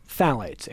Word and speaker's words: phthalates: Phthalates, P-H-T-H-A-L-A-T-E-S. You're smiling at me. Am I pronouncing it correctly? Are we spelling it? – I think the PH is silent, so I think it's just phthalates.